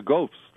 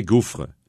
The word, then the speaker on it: gaufre: Yes, the gaufres, the most delicious waffles in the world. – Gaufre, that's how you and I would pronounce it. Because we say gouffre.